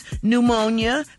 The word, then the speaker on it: pneumonia: Number two is pneumonia because the P is silent.